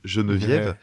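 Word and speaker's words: Genevieve: C'est donc euh, la, la, chanteuse, euh, donc euh, Geneviève, je vais le prononcer à la française, hein, Geneviève.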